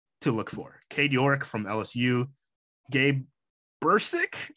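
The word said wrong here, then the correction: Brkić: I think is how you say his name, Brkic. Oklahoma. Brkic. Brkic.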